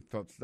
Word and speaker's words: thoughts: Alors on va prononcer thoughts là faut mettre langue dehors comme ça. Alors, si vous êtes capable de faire ça, commencez par thoughts toujours, et puis vous faites un entraînement devant la glace.